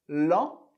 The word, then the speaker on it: lent: Lent, que significa lento. Lent. Você já sabe que o T do final a gente não pronuncia, por isso lent.